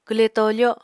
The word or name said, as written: kwle tofo